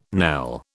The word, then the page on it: knell: British English